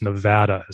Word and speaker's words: Nevada: And I've said Nevada a couple times in the past, and I'm always corrected by locals that it's Nevada is the correct, that fat A. Is the right way to say it.